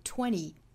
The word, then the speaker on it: twenty: 这里有一个小的差别，就是在美音里比较多见的就是二十的读法，在口语里啊，美音经常会把这个词读成 twenty twenty，而不是我们看到的音标是 twenty twenty。经常会听到把这个 t 的这个爆破音给略掉，也就是前面的这个鼻音嗯的这个音跟后面直接结合了，发成了一个你你的发音，也就是 twenty twenty。